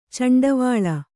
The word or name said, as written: ಚಂಡಿವಾಳ